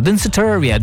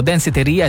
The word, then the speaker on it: Danceteria: Andiamo avanti con la musica, qui c'è Danceteria, come l'ho detto bene questa volta, Danceteria. Dopo due mesi ci sono riuscito, perché prima dicevo Danceteria e sembrava tipo un negozio, una gelateria, ma invece no, Danceteria, e lei è Madonna.